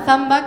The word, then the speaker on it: zamba: No es la zamba brasilera, esta se escribe con Z. Pasa que como nosotros no pronunciamos bien la Z, no se entiende bien, pero es zamba.